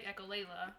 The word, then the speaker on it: echolalia: like repeat, like echolalia. – Yes. Yeah. – Echolalia. – Yeah. Yep. Yep. You said it right.